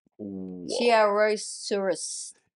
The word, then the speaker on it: chiaroscurist: I'm like, I've literally got how to pronounce it. And it's so you're closest, Ari. So it's chiaroscurist.